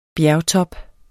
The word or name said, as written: bjergtop